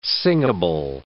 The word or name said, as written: singable